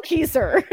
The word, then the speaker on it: Keister: As she struggles to figure out who wrote the note, she discovers a printed book in her Insta closet called the Jesse Keister. No. – Keister. – Keister. – It's spelled Keister. – I think like Geyser. Kaiser. – Yeah.